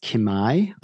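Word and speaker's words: Kimaï: And the company was called, I believe it's pronounced Kimai. I might be butchering that, but it's K-I-M-A-I. I with an umlaut over it.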